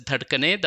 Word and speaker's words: dhadkane: Like if you think of the words dhadkane and dhadakne, you pretty much write them in exactly the same way, but one you pronounce as dhadkane, the other one you pronounce as dhadakne.